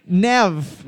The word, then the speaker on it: Neve: Nev.